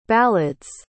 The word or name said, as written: ballad's